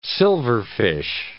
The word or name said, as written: silverfish